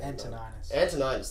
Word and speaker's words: Antoninus: The original version included a scene where Crassus and Antoninus, fucking super weird to pronounce that name. – Antoninus, thank you.